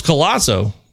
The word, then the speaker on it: Colazo: We had our MLB draft preview expert on from Baseball America, Carlos Colazo. That's right. It rhymes with Palazzo.